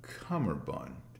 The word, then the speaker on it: cummerbund: That's what I've always known it to be until recently when I found out that it was not pronounced that way. It's actually a cummerbund. And that's C-U-M-M-E-R-B-U-N-D. Cummerbund.